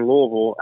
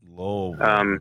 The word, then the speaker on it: Louisville: Yeah, I mean, but if you want to sound local, it's fucking Louisville. I'm between Louisville and Cincinnati. – Louisville, okay. – Louisville, it's two syllables, Louisville.